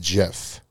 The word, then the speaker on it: GIF: Is it pronounced GIF or JIF? – It's pronounced JIF.